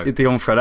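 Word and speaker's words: D'Onofrio: Dionfrio, that's it.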